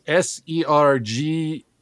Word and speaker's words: Sergei: By the way, my name is spelled Sergei, and you spelled it SIR GAY. So it's phonetically correct, but is not the way I spell it, okay? – That was a joke.